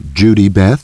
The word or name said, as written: Judy Beth